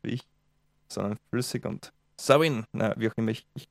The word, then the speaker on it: Samhain: Ah, wir haben angeblich spricht man Samhain Savin aus.